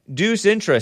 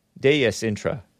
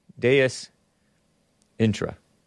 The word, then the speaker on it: Deus Intra: The name is pronounced Deus. Oh, Intra. Deus Intra. Oh. Huh. Which is Latin for a God within. Deus Intra. Nice. Thanks, man. I butchered your name right before reading your correction. Not Deuce. Deus. D-E-U-S-I-N-T-R-A.